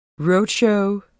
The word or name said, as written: roadshow